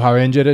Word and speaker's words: Avengers: Cuando salió la última de los Avengers, que yo, o los Avengers para nuestros, compa nuestros radioescuchas caleños. – Los Avengers está bien pronunciado.